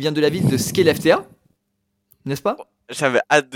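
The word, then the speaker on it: Skellefteå: Parce que vraiment, en suédois, il dirait Relefteå.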